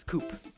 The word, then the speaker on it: Coup: And on today's episode of Beyond the Board, we'll be discussing the themes behind the game Coop. – Yes, Coop. Uh, the P is silent if you haven't played Coop.